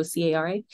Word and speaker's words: Cara: It's Kara.J.Myers, and spelt with a C, so Cara, because my mom wanted to be unique and no one could pronounce my name. They always call me Kara.